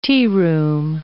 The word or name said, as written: tearoom